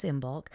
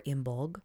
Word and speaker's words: Imbolc: and one of them is Imbolc, or Imbolg, depending on how you want to pronounce it, and it's halfway between the winter solstice and the spring equinox.